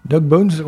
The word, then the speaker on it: Dog Bones: Et donc le groupe, alors si je le prononce bien, c'est les Dog Bones, ou Bones, je sais pas comment on prononce. – Bones, B-O-N-E-S. Dog Bones, ouais.